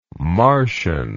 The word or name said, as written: marcian